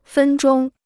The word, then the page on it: 分钟: fēn zhōng